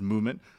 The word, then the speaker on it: MVMT: Movement Watches, this is spelled MVMT, but pronounced Movement, was founded on the belief that style shouldn't break the bank.